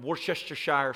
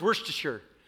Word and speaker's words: Worcestershire: Well, like I like to say, the three hardest things in the world to say, forgive me, I need help, and Worcestershire sauce. That's a tongue twister every time. It depends where you're at. Where I grew up, it's Worcestershire. Worcestershire. We don't even bother trying to make a whole word.